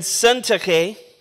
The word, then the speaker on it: Syntyche: I implore Euodia and Syntyche. That's right. I did not mispronounce it. I practiced those names. That's how you do it.